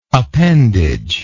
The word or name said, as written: appendaged